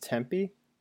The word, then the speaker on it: Tempe: Like, Wait, it's pronounced Tempe? – yes.